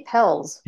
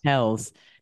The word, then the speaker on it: Pelz: Mindy Pels. – Pels.